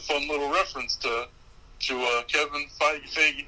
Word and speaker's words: Feige: We're also in New Jersey because apparently, and I didn't know this, Kevin Feige, how do you say his last name? – Feige.